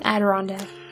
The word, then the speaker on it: Adirondack: Adirondack, is that how you say it? – Adirondack.